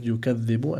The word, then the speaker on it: يكذب: And then the Sheikh moved on to the third word, يكذب, and he also explained that the ذال over here has a شدة on it and it's من حروف اللثوية. So we need to be careful not to mix it as well with another letter like الزاي, because a lot of people may mispronounce the word, and this may also change the meaning or not give the word the right meaning.